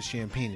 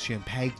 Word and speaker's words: champagne: They don't pronounce it champagne. It's champagne.